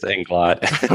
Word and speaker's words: Inglot: It's Inglot.